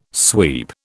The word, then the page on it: sweep: British English